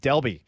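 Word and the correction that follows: Dalby: It's actually Dolby.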